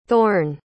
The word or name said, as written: thorne